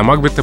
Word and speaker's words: Макбет: А дело в том, что по правилам произношения в английском языке надо говорить «Макбет», приставка «Мак» никогда не бывает ударной. Угу.